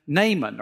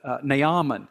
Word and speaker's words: Naaman: He, through his prophet Elisha, arranged for the healing of a man named Naaman, or some pronounce it Naaman.